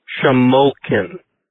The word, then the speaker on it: Shamokin: It's pronounced Shamokin. I don't know where Shamokin came from, but it sounds like a bad parody of a Mel Brooks film. So, anyway, Shamokin. You're going to be coming to AOAA. You should probably pronounce it right.